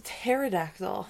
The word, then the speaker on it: pterodactyl: No, that's a pterodactyl. The P is silent.